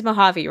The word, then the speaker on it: Mojave: It is Mojave, right? – It is pronounced Mojave.